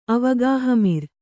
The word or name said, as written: ಅವಗಾಹಮಿರ್